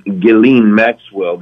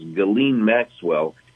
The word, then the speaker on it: Ghislaine: Ultimately, they got it when Epstein died, and Ghislaine Maxwell, the S is silent, I think it's pronounced Ghislaine Maxwell, who ended up taking the fall.